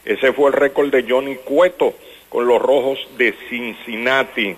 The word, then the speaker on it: Johnny Cueto: Johnny Cueto, eso es facilito, pero los gringos no pueden decir Johnny Cueto, ellos dicen Johnny Cueto.